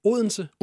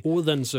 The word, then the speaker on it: Odense: It's so hard to pronounce like Unsen. – Odense, that's crazy. – Odense, yeah. – It's like a dance music. That's how I remember how to say it.